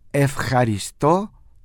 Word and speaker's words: ευχαριστώ: Por lo tanto, es ευχαριστώ πολύ. Ojo con la pronunciación de ευχαριστώ, la J, igual como pronunciamos J. ευχαριστώ, con el acento en la O, la última O, y πολύ, acento en la I.